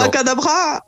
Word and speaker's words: abracadabra: ¿cómo se dice abracadabra en francés? – Yo creo que no. – Abracadabra. – Abracadabra. – Eso es. El acento al final.